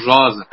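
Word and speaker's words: Raz: آقای جوزف راس که گفتیم فیلسوف اسرائیلی هم هست، که من تلفظ صحیح اسمشون رو راز یا رز شک داشتم، که یکی از دوستان خانم دکتر صدری به هم گفتن که بله همون راز هست، چون به اسرائیلی هم هستن، همون راز خودمون، راز و اسرار اینها.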